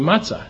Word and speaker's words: matzah: And I thought maybe it was, you know, his pronunciation of matzo, or, you know, I was ill-informed. The correct Hebrew term is actually matzah.